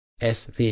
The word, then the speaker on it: ESWE: Und klar, dann freuen wir uns auch immer, wenn wir richtig ausgesprochen werden als SW.